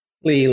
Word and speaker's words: Lee: Lee or Lay? – Lee, like Bruce Lee.